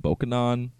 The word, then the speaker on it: Bokonon: the like fake Creole patois of the people of the island pronounced his name Bokanon.